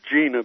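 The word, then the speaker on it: Jena: I think they pronounce it Gina, but I think it's spelled Jenna.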